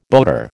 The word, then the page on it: boater: American English